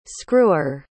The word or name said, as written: screwer